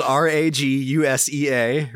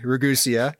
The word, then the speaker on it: Ragusea: which is spelled Ragusea, Ragusea, but it's pronounced Gabagool, and you can do the same on YouTube.